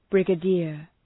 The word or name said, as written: brigadier